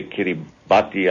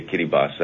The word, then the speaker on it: Kiribati: Well, you say Kiribati, I say Kiribati. That's how they pronounce it, and out of respect to the people of Kiribati, I pronounce it that way. – Kiribati? – Kiribati. The T is silent in the...